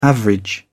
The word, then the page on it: average: British English